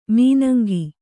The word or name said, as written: ಮಾನಂಗಿ